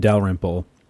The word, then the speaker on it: Dalrymple: I think it was Jim Dalrymple. Am I saying that right? – Mm-hmm.